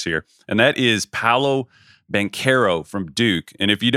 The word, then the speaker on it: Banchero: Apparently it is Banchero, 6'10".